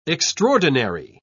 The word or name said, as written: extraordinary